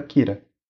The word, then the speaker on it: Kira: Então, killer, aquele R no final, Some. Então vira Kira.